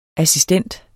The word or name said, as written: assistent